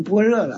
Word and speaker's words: 般若: 哦，那个若就是那个用一下就尼亚，就这个就那在古代一念就是巴尼亚，就这么念的。这就现在念成般若了。